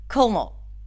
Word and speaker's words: colonel: And I remember trying to imitate their pronunciation, and I knew that there was an L there, so I would always try to say it really quickly, Colonel.